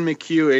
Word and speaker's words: McHugh: Assistant, but it's at N McHugh 85, and my last name is spelled M C H U G H, and that's about it.